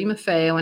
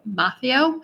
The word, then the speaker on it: Marfeo: I have to interrupt myself for a moment to say that one of our listeners told us the proper way to pronounce Maffeo, Maffeo, I believe, but Nina and I are so used to pronouncing it the wrong way that I'm not sure we can correct ourselves, so please don't be angry at us.